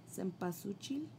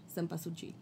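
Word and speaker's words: cempasúchil: um, and also bringing, um, I always forget how to pronounce this word, sempachulchil, Cempasuchil. Yeah, Cempasuchil.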